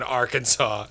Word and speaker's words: Arkansas: Or don't pronounce the last S in Arkansas at 4 years old.